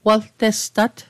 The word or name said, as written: waltestat